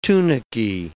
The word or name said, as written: tunicae